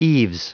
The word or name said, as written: eaves